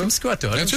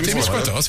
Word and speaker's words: Témiscouata: C'est-tu Témiscouata, c'est ça? – Témiscouata. – Témiscouata, c'est bon.